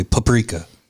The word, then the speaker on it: paprika: Paprika, like P-A-H, not P-U. Okay. Paprika, like puppy.